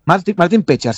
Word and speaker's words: Pečar: En el caso de Martin es Pečar, ¿no? – Martin Pečar, sí. La tilde que no existe es que en la E.